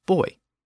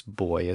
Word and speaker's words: buoy: In Britain, the pronunciation is buoy, and it's my understanding that that's also the pronunciation in Australia. And it also appears that Canada tends to use buoy as well, but buoy also makes an appearance in certain areas, perhaps due to U.S.